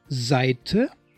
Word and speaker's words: Seite: E-I is pronounced I, like in Seite, Weiter, Leicht.